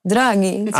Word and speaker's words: Draghi: Led by Mario Draghi. I don't know how to pronounce this correctly in Italian. Do you know? – Draghi.